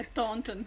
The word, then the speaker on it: Taunton: É Taunton.